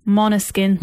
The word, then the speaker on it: Måneskin: It's pronounced, well, it's spelled Måneskin, but it's actually pronounced Måneskin.